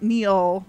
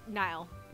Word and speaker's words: Niall: But it's not Neil. Niall, maybe. – Let me look that up. – I don't want to mispronounce it and make it even worse. – No, the One Direction kid is Niall.